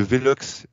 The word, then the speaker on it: Velox: Euh, qu'en est-il de Velox C'est comme ça qu'on prononce.